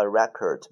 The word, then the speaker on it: record: 下一个 record，这个单词有两个发音，第一个读 record，record，第二个读 record，OK 啊。那么 record 的意思叫做唱片，buy a record，买一个唱片。